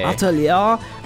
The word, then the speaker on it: Atelier: It's actually Eight Liar. – Saying it was French. This Atelier. – Atelier.